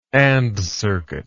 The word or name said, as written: AND circuit